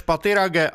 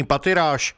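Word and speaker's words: Pathirage: Vyslovoval své jméno Rumeš Patirage a vyloučil anglickou výslovnost svého příjmení Patiráš.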